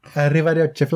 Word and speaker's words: Arrivederci: You just have to roll your R's more. – Arrivederci, Flavia. – Yes. But less like a Mario brother. – Arrivederci, Flavia. – Yes. Much better.